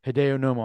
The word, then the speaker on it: Hideo Nomo: And it was just he was just one of the first guys I remember seeing, like Hideo Nomo.